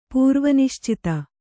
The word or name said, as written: ಪೂರ್ವನಿಶ್ಚಿತ